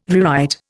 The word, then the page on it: rewrite: Indian